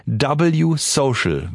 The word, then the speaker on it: W Social: W-Social wird es ausgesprochen. Ein W-Social, so wird's geschrieben.